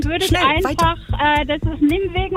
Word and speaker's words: Nijmegen: Richtig ausgesprochen, die Niederländer sagen so. – Nijmegen.